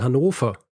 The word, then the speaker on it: Hannover: Entgegen meiner wiederholten Aussprache während dieser Folge, heißt diese Stadt nicht Hannover, sondern Hannover. Hannover, ja. Mit einem F. Ja? F wie Friedrich. – Ja. – Nicht W wie Wilhelm. – Ja. Hannover. – Hannover.